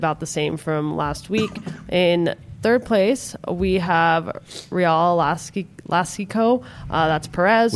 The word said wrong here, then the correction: Jalisco: I said it wrong, I think. Real Alaska. – Jalisco? – Jalisco. There we go.